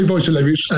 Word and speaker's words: Wojtulewicz: You say Wojtulewicz, and unless you're Polish, I don't expect you to get it.